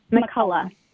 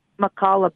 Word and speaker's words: McCullough: I'm also wondering, I know that Dr. Malone and Dr. is it McCullen? How do you say his last name? Peter McCall? – McCullough. – McCullough.